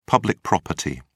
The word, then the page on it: public property: British English